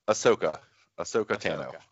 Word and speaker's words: Ahsoka Tano: is it a Soto? – Ahsoka Tano.